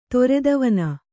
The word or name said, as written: ತೊರೆದವನ